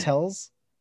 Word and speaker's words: Tells: Tells, like tells.